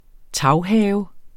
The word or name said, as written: taghave